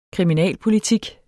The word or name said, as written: kriminalpolitik